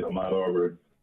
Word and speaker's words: Ahmaud Arbery: I want you to tell us what's happening with this, and correct me if I'm mispronouncing the brother's name, Ahmaud Arbery? – That's it, Ahmaud Arbery.